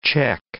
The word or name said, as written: check